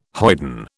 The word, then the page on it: hoyden: British English